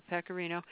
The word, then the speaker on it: pecorino: You know, the things that I've come across are, and I haven't expanded past manchego and pecorino cheese.